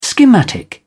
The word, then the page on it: schematic: British English